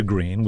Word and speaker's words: Gruene: Yeah, I have been to Green, which is spelled G-R-U-E-N-E, right? – Right.